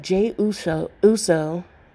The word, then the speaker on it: Jey Uso: But, yeah, so I do think Jade is going Jade. I keep saying his name wrong. I do think that Jey Uso should win this match between against his brother, Jimmy.